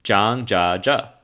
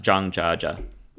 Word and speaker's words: Zhangjiajie: Zhangjiajie. I looked up the pronunciation. – Oh, wow. – It's spelled Zhangjiajie, but it's pronounced Zhangjiajie.